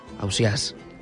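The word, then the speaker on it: Ausiàs: si es pronuncia Ausias, Ausias, Ausias... En definitiva, Ausias.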